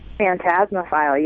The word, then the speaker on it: Phantasmophile: And I'm really curious because you have like a really, your website, how am I going to pronounce it right, Phantasmophile? – Phantasmophile, you got it. – Good, I got it right, which is totally awesome.